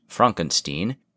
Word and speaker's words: Frankenstein: Specifically, the scene in which Dr. Frankenstein, pronounced Frankenstein,